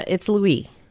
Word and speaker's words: Louis: It's Louis.